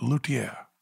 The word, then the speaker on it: luthier: It said, luthier. – Uh-huh. – Oh. – Luthier. And I went, I've been mispronouncing it. I have been mispronouncing this word.